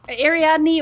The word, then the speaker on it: Ariadna: It's A-R-I-A-D-N-A, Ariadna. – It's Ariadne, or as it was originally spelled, Ariadna. But yeah, no, you've got it right. I wonder, though, if her given name was Ariadna, and then just to make it a little bit more mainstream spelling, she later changed it.